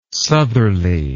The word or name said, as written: southerly